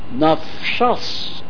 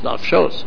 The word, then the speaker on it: Nafshos: Rabbanon, but the rabbis, nafshas kesiv. They read it in the Torah. I mean, they know it should be pronounced Nafshos.